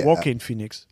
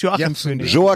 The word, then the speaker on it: Joaquin Phoenix: Joaquin Phoenix wird da ausgesprochen. Wir haben Ärger gekriegt. – Joaquin. – Du hast Joachim Phoenix.